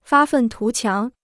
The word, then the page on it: 发愤图强: fā fèn tú qiáng